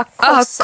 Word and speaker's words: COSRX: Och, och jag vet inte hur man uttalar det men jag tror Kosser COSRX eller, eller bara COSRX. Ah, COSRX.